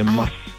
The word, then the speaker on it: Maß: Die Betonung ist auf dem S, also eine Maß.